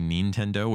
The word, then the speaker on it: Nintendo: They always say Nintendo, which is the right way to say it in Japanese.